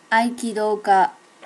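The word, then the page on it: aikidoka: native Japanese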